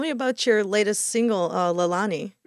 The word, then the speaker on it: Lailonie: You've pronounced it perfectly, actually. Most people get that wrong. But yeah, so Lailonie, it's named after my wife, Maddie. It's one of her middle names. It's a Hawaiian word, which means heavenly flower.